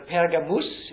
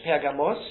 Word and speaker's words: Pergamos: Oder Pergamus ist Lateinisch, Pergamos ist Griechisch.